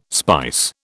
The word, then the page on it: spice: British English